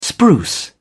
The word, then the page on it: spruce: British English